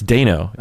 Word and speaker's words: Dano: It's Dano, not Dano? – It's Dano, yeah. Okay. I watched him on Hot Ones where they eat the hot wings, and he was introduced as Paul Dano, and he didn't say anything.